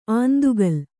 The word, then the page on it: ಆಂದುಗಲ್: āndugal